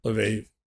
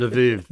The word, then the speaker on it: Lviv: Currently, it's in a sort of a temporary situation in Lviv. Is that how I pronounce it? – Lviv. Lemberg, yeah, if we're going to go with Ludwig von Mises. Lemberg. – Right. – Lviv. – That's right. Exactly.